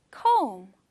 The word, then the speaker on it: comb: And finally, comb. – Comb. 이 뒤에 B가 있기는 한데 그거는 발음하지 않아요. 그냥 comb으로 발음합니다.